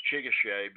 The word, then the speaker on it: Chickasaw: I pronounce Chickasaw because that is how Chickasaw is supposed to be pronounced.